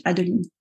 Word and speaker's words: Adeline: Und mein Vorname ist französisch Adeline. Das mhm. ist die richtige Aussprache.